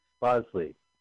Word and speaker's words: Bosley: Bosley.